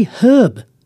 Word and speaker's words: herb: We say herb. But did you know that the Americans don't pronounce the H? Herb. That's what they say.